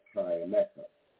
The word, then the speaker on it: Tyemmecca: My Instagram page is Divine Prince Tyemeca, T-Y-E-M-M-E-C-C-A.